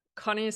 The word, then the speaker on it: Conni: Connie sounds perfect, and last name is Bizaski.